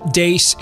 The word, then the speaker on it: Deace: Follow us on Twitter at Steve Dace Show. Don't forget, last name is spelled D-E-A-C-E.